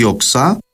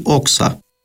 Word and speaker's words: όξα: Κάποιοι προφέρουν την με τόνο στη λίγουσα, δηλαδή οξά, ενώ άλλοι προφέρουν την με τόνο στην παραλίγουσα, δηλαδή όξα.